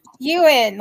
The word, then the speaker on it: Ewan: Ewan, like Ewan McGregor.